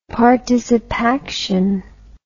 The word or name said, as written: participaction